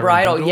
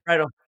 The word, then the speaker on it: Bridle: And you and I will be in here next week with Dr. Brindle, is that how you pronounce his last name? – Byron Bridle, yes. – Wish we could get back on Twitter. – Bridle.